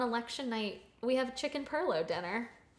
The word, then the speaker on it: pilau: Yeah, because it's spelled P-I-L-A-U, but it's perlo.